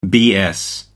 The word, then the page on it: bs: American English